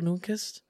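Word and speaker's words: Nyqvist: I think I pronounced that right. Michael Nyquist? – Yeah, go for it.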